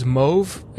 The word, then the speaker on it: mauve: So it's spelled M-O-E-V, by the way. – Mm-hmm. – It's a color, and the color is Mauve. It's M-A-U-V-E. It's like a pretty purplish pink color.